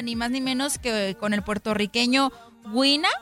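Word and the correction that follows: Guaynaa: así se pronuncia. Guaina. Guaina. Sí.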